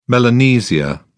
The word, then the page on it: Melanesia: British English